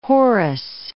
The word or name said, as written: Horus